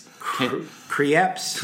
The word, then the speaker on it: Krieps: Stars Daniel Day-Lewis, There Will Be Blood, and newcomer Vicky Creeps. Am I saying that right? It can't be Creeps. – Creeps. – K-R-I-E-P-S.